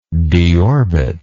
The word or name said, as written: deorbit